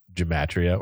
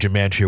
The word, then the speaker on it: gematria: It's G-E-M-A-T-R-I-A, gematria or gematria. I think it's gematria. The shit they're pulling out of their pocket. – Yeah. – Back to it. Hebrew, hashtag gematria, word value for Zena Taylor.